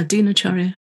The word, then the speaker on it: Dinacharya: Yeah, Dinacharya, yeah.